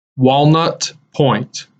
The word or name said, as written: Walnut Point